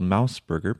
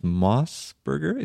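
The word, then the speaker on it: MOS Burger: I used to think it was called Mouse Burger because it's MOS, but I guess it's pronounced Moss Burger.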